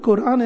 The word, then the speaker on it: Quran: And then there is the largest chapter of the Quran is the second chapter. Actually, the word Quran is not correctly pronounced. It's Quran, which means Quran means the reading.